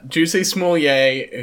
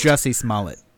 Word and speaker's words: Jussie Smollett: Juicy Smollier, who I am going to keep pronouncing his name like that because I've honestly forgotten how his name is actually pronounced. – Jussie Smollett.